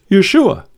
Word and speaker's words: Yeshua: It's pronounced Yeshua, and it means God saves.